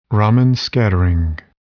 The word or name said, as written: Raman scattering